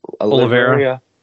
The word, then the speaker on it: Oliveira: Oliveira.